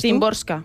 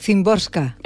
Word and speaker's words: Szymborska: ¿Cómo lo pronuncias Szymborska. – Tú? Szymborska.